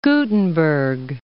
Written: Gutenberg

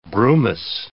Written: brumous

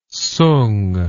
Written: soong

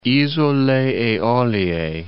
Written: Isole Eolie

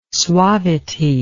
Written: suavity

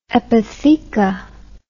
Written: epitheca

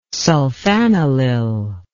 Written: sulfanilyl

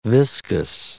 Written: viscus

